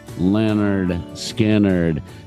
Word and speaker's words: Lynyrd Skynyrd: Lynyrd Skynyrd, the debut album, pronounced Lynyrd Skynyrd.